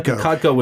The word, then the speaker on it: KODCO: KODCO would be how you'd be pronouncing this.